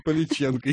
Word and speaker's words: Поличенко: А говорить мы сегодня будем об одной, наверное, знакомой вам девушке, которую зовут Татьяна Поляченко. – Поличенко. – И здесь с ударением можно перепутать. – И здесь с ударением, да.